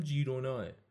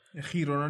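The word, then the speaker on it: Girona: این تلفظ درستش هم جیروناه خیرونا نیسته خیرونا به مادریدی میشه به کاتالانی که این تیم هم مال همون ایالت کاتالونیاس همون جیرونا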